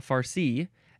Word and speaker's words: farci: Emphasize the second syllable, farci, F-A-R-C-I, or you can put an E at the very end.